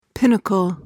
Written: pinnacle

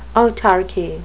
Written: autarchy

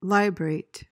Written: librate

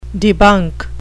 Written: debunk

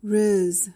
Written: rizz

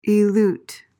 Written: elute

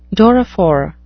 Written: doryphore

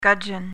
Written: gudgeon

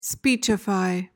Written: speechify

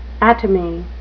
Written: atomy

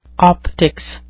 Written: optics

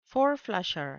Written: four-flusher

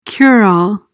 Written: cure-all

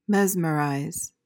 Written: mesmerize

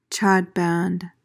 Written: chadband

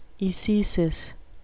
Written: ecesis

